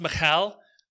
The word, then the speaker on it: Michal: I, okay, it's technically pronounced Michal.